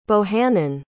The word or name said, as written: bohannon